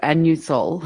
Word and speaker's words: Anusol: Do you pronounce it anus all? – If you ask for it over the counter, you ask for anusol, okay. But it does look like anusol. – I call it anusol. So if I had to ask for it over the counter, I would say it like that.